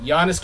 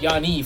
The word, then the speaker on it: Giannis: Or actually, not Giannis, Yanni for all the Greeks listening. – Yanni. – Because you do not pronounce the S. Otherwise, it's like saying John's.